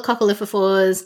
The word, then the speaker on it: coccolithophores: All the coccolithophores, did I say that right? Coccolithophores? – Yes, you did.